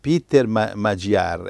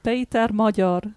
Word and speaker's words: Magyar: Allora si dice, cioè in italiano questo lo leggono Peter Magyar, e tu lo pronunci? – Peter Mojor.